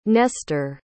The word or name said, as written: Nestor